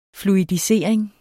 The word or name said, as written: fluidisering